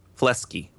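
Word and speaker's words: Fleksy: It's called Flexi, but how do you say it? Flexi? – I thought it was Flesky. – There you go.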